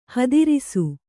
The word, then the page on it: ಹೆದರಿಸು: hedarisu